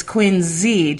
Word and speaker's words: Quincy: If you're from around here, or even from the Boston metro area, they don't call it Quincy. That's how they know you're not from around here. – Mm-hmm. – It's Quincy, just like all the other towns around here in Massachusetts aren't pronounced the way that you'd see it.